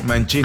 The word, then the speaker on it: Mancini: creo que el apellido Mancini de Don Henry se pronuncian con... – Sí, Mancini. – Ah, es Mancini. – Sí, es correcto.